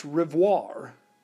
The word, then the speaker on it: Rivoire: Well, it turns out that Revere in French was pronounced Rivoire, R-I-V-O-I-R-E, Rivoire. Paul Revere's father was Apollos Rivoire,